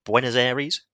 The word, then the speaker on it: Buenos Aires: I believe he is based 30 kilometres outside of Buenos Aires.